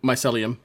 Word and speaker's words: Mycelium: Mycelium? – Mycelium? – Is that the right word? Yeah, that the right one? – Mycelium.